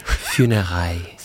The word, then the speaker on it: Funérailles: Funérailles.